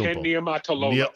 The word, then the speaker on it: Ken Niumatalolo: Ken Niumatalolo.